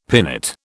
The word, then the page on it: pinnate: British English